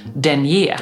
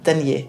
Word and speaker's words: denier: Det finns ofta ett svenskt uttal. Men just vad det gäller tätheten på strumpbyxor så är vi ju nu inne på att det stilsäkra uttalet är ändå denier. – Ja, precis. – Eller hur? För det kommer ju från, härstammar från franskan.